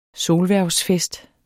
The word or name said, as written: solhvervsfest